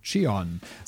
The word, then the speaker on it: Chijon: I've got two main pronunciation dictionaries that I use, and neither of them had this, so I've just relied on Google Translate, which tells me that it's pronounced as such. Chijon. So I'm hoping that's right.